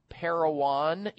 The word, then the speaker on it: Parowan: Is it pronounced Parowan, Utah, Chris? – Yes.